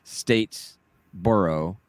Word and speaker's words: Statesboro: We're heading down to Statesboro, and it's pronounced Statesboro.